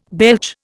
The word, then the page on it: belch: Indian